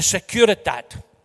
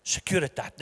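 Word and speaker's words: Securitate: The Securitat, pronounced perfectly, Securitat,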